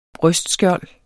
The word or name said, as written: brystskjold